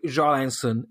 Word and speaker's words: Göransson: And Christ, I mean, Gorans is on a hot streak for, oh, sorry, Ludvig Jarlansson is how you say it, apparently, in Swedish.